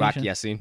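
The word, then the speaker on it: Rock Ya-Sin: Not at practice, Braden Smith, Quentin Nelson, Kari Willis, and Rock Yacine. Was that the pronunciation? – Rock Yacine. – Yes. Mm-hmm. So yep. That's it. – Hey, you got it.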